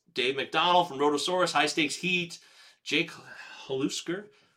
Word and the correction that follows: Halisker: Halisker. That's right. Last year, I finally learned how to say his name.